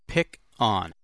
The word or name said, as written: pick on